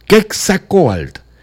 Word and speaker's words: Quetzalcóatl: Es el dios tolteca Quetzalcóatl, vamos a llamarlo así, porque tiene un acento en la O. Quetzalcóatl, regaló la receta original de su pueblo.